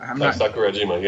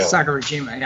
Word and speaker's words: Sakurajima: So I know, is it Sakajiwara? – Sakurajima, yeah. – Sakurajima, yeah, I can't always say it wrong.